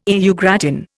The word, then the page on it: au gratin: Indian